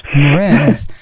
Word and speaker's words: Mraz: Nick Kershaw en Jason Mraz. Spreek je dat zo uit? – Ja, Mraz.